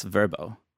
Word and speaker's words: Vrbo: That's Vrbo. I think that's how you say it. It has no E. V-R-B-O.